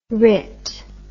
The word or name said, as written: rit